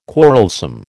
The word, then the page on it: quarrelsome: American English